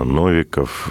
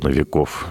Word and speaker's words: Новиков: А ко всем остальным, кого многие историки называют, там тот же Суворов, Кутузов, Пушкин, Грибоедов, э, Новиков, э, Новиков, вернее, правильно говорить, э, Елагин и так далее.